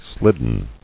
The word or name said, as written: Slidden